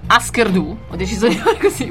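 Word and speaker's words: Hüsker Dü: Husker Du, pronuncia per favore il corretto Husker norvegese, sì, che è un po', diciamo.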